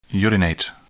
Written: urinate

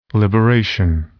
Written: liberation

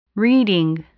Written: reading

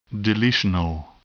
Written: deletional

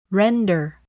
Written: render

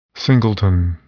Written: singleton

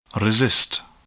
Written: resist

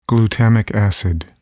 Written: glutamic acid

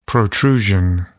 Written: protrusion

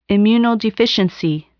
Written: immunodeficiency

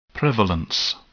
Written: prevalence

